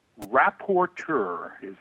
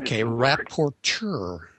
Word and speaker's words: rapporteur: I hope I don't butcher this function that you had, but I think it's reportier. Is that right? – Rapporteur. – Rapporteur. All right.